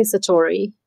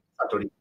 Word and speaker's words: Satori: How do you say it with a Japanese accent? Because I'll say Satori, and I know that's not right. – Satori.